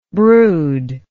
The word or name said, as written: broodless